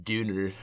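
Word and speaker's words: Döner: I think if you're being local about it, it's like Duner, you know, because it's got the umlaut over the O by technicality, too.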